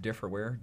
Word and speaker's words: Difrwear: It's pronounced Differware. – Differware. Did I say it right or wrong? – That's it. Differware. Like different. – Okay. It's spelled D-I-F-R-W-E-A-R.